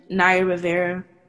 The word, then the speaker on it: Naya Rivera: Naya Rivera,